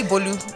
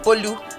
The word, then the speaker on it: Bolu: So it's actually Bolu. – Bolu? Okay. – You do the Lu is up. Bolu.